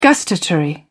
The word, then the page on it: gustatory: British English